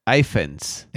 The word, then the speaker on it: Ifans: I-F-A-N-S. – Iphans.